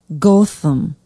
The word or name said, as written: gotham